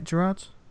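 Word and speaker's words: Gerads: Is that how you say it, Gerards? – I think so, yeah. I think that's right.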